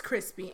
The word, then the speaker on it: Chrispie: And my name is Crispy, and that's spelled C-H-R-I-S-P-I-E.